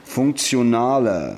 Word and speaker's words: Funktionaler: Ist doch kein Z. – T-I wird auch Z ausgesprochen. – Also heiße ich Zibor? – Funktio. – Funktionaler.